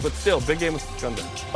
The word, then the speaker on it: Gonzaga: Women's Hoops hosts number 18 Gonzaga. That's how you say it, by the way. – Mm-hmm. – It's not any other way.